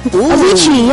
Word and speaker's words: Avicii: Avicii, yeah.